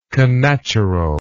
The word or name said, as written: connaturalness